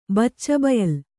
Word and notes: ಬಚ್ಚಬಯಲು: baccca bayalu

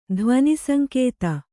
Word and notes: ಧ್ವನಿಸಂಕೇತ: Kannada

